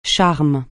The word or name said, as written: charme